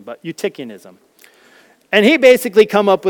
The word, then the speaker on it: Eutychianism: This is how I was taught to pronounce this. I'm not quite sure if it's the way all of would have been taught to pronounce this, but it's called Eutychianism.